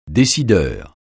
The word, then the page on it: décideur: French